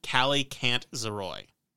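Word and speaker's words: Kallikantzaroi: This, if I'm pronouncing it correctly, I watched a lot of videos to see, and it seems like for the most part, people are pronouncing it Calicantzaroi.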